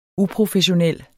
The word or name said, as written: uprofessionel